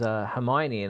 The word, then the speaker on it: Hermione: Always when I was reading the books, and there was Hermione, and I was like, Hermione was the way I was pronouncing it.